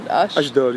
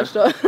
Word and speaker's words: Ashdod: Ashdod, yeah. Ashdod. I mean, technically I was born in Rehovot, which is like the hospital is there, but I mean, I was born and raised in Ashdod.